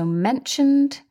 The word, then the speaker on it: mentioned: So mentioned, the ED is pronounced D, mentioned.